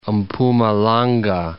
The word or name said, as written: Mpumalanga